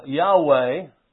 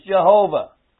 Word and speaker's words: Jehovah: And so the liberals have come along and renamed the Lord Yahweh, which is not a biblical name. And we're to praise the Lord's name, and how are we going to praise His name if we don't know how to pronounce it? We know how to pronounce it. It's Jehovah. Amen.